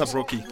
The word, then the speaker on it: ASAP Rocky: il a, il a sorti une version avec ASOP Rock, je sais pas comment ça se prononce. ASAP Rocky. ASAP Rocky, voilà.